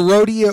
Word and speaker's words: Rodia: Or Rodia. How do you pronounce it? – Either Rodia or Rodia. – R-O-D-I-A.